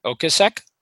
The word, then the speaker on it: Ocasek: Ocasek? – Sure, that guy.